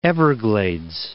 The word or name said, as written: Everglades